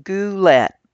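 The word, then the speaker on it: gulet: It's pronounced goulet, by the way.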